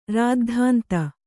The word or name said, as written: ರಾದ್ಧಾಂತ